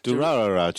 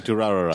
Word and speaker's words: Durarara: I can't pronounce it properly. I'm sure I put a few extra ra's in there. But Durarara. Just call it Durarara. – Durarara. It's only three ra's in it.